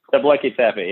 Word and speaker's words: Göbekli Tepe: Tegucigalpa Tepe. Am I saying that right? – Göbekli Tepe, I think. – Göbekli Tepe, yeah.